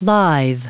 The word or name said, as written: lithe